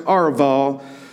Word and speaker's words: Arabah: Now, verse 8, this word that looks like it says Arabah, which is actually pronounced Arval, it appears 61 times in your Bible.